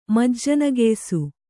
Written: ಮಜ್ಜನಗೆಯ್ಸು

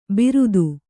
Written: ಬಿರುದು

